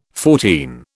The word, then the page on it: fourteen: British English